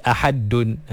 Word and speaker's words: ahad: Kalau kita baca ahad, maknanya kat sini ejaan dia dah berubah. – Okay. – Dia dah jadi ahadun. Ha, jadi qul huwallahu ahadun. – Baik. – Ahadun. Jadi waqaf jadi ahad.